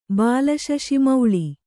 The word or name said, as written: ಬಾಲಶಶಿಮೌಳಿ